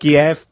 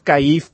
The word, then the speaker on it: Kiev: una paraula com Kiev, no?, aquesta capital d'Ucraïna, són quatre lletres que nosaltres hem arribat a pronunciar de cinc maneres diferents. – M'ho crec. – Kiev, Kiev, Kaif, Kiev, i l'altra combinació que em falta, no?